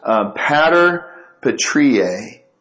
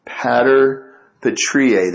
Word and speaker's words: Pater Patriae: It was Pater Patriae. Pater Patriae.